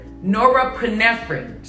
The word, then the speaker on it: norepinephrine: Norepinephrine.